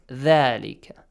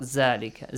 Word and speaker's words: ذلك: Thalika, not Zalika. Zalika has another meaning. Might be, it might not exist even in Arabic.